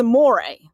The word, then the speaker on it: Amore: So I watched her all summer when my racehorse had her, and we got all the works, and it was Amo Ray, is all everybody says. It's Amore, like love.